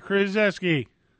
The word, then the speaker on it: Krzyzewski: Or Coach CH, I don't know how he pronounces it. – Krzyzewski, Krzyzewski. – Yeah, that's what I said, Krzyzewski. Only the last three letters are correct.